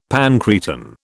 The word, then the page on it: pancreatin: British English